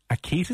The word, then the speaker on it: Akita: They're American Akitas?